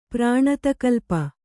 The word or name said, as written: ಪ್ರಾಣತಕಲ್ಪ